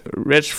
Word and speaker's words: Wretch: Monsieur euh, Rich 32, en fait, Wretch 32, c'est comme ça que lui veut qu'on le prononce, vient de Tottenham.